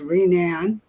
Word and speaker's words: Rene: Rene, I'm Goodrich, Jogunash Shimong, Mushkazibing, and then doing Jabab. I'm from Bad River. I'm Bad River Tribal Elder. My name is pronounced Rene, Robert.